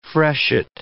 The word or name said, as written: freshet